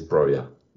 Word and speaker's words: Broja: I think it's pronounced Broja. – I did have a look. I've been in the off season. I've been learning a bit of Albanian. And of course, yeah, you have. I mean, why wouldn't you? – What else are you going to do with that weekend off? And it turns out it is Broja.